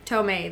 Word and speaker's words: Tomei: Tomei, there we go.